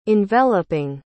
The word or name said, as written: enveloping